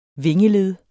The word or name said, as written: vingeled